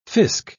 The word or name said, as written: fisc